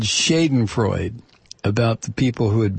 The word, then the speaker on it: Schadenfreude: It's understandable for English speakers to make your mistake of not pronouncing the ending I in Schadenfreude because of the silent E at the end of so many of our words. But an E at the end of German words following a consonant is almost always pronounced a, as in Porsche, not Porsche.